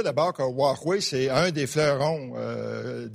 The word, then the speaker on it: Huawei: Le 1er décembre dernier, le Canada a arrêté Mme Meng, une dirigeante de la compagnie de télécommunications Huawei. Je l'ai bien prononcé. Oui, oui. Huawei.